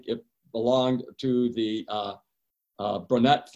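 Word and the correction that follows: Brontë: family, Charlotte Brunette, if I'm pronouncing the name right, the English author who wrote the book known as Jane Eyre. Bronte, thank you.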